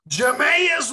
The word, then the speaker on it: Jameis: And on the board, we have Jameis Winston, or how do you say it? – Jameis Winston and Marcus Mariota.